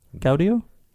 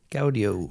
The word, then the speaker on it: Gaudio: Gaudio? – Gaudio.